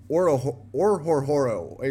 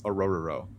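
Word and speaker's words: Orhorhoro: The H's are silent, so it's just Arororo.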